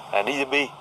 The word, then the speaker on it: djembe: Mình dễ phát âm đọc là djembe.